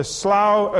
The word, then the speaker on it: slough: Now, I pronounced it slough. I did check with a number of people here to find out how Americans pronounce it, and I came up with slough, slough, and slough. So you can take your pick just so long as you don't slough off while I'm talking.